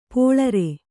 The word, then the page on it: ಪೋಳರೆ: pōḷare